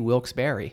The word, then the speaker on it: Wilkes-Barre: If I'm not lazy, Wilkes-Barre, because it was Wilkes and Barry. – Yeah. I've never been a Wilkes-Barre guy.